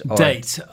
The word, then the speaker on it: Date: Date.